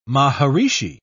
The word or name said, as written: maharishi